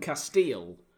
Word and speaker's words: Kasteel: is this glass from Castile, which is a Belgian brand. It's spelled K-A-S-T-E-E-L.